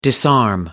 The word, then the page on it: disarm: American English